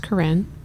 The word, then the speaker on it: Carin: And it's spelled Carin, and it's pronounced Corinne.